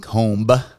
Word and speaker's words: comb: You don't pronounce the B in comb.